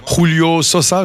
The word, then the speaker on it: Julio Sosa: Et là, on entend Julio Sosa, j'ai prononcé juste.